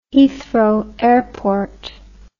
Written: Heathrow Airport

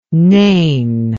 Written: nane

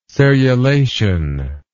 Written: serrulation

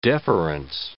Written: deference

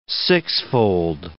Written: sixfold

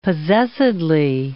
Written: possessedly

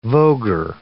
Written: voguer

